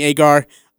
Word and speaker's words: Agar: This is our interview with Annie, and I call her Agnar. That's my bad. Sorry. I tell you, I kept calling her Agnar, and then before our interview started, I was like, hey, how do you say her name? She's like, oh, it's Agar.